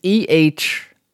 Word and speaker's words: Ehrenreich: and people coming out even saying Alden Heidenreich. Am I pronouncing that right? It's from across the table. – It's Ehrenreich. So something however like German you want to go.